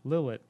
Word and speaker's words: Lillet: And one thing that he talked about is that the Kina Lillet, or I don't know if it's pronounced Lillet. – Probably, oh, it's Lillet, yeah.